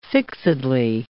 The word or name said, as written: fixedly